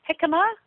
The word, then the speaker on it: jicama: Jicama, you may ask, what is that? It's pronounced jicama, but you spell it J-I-C-A-M-A.